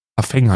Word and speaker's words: Parfum: Parfeng heißt das, wird das ausgesprochen.